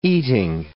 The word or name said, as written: eating